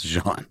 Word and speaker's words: genre: The ruh in genre is like a quick syllable. – John. – It's John.